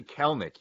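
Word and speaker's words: Kelenic: No, we're talking Jared Kalanick, but Kalanick's too. Hey, man, he's in L.A. But I'm sorry, what is the pronunciation there? – It's actually Kelnick. You don't say the other E.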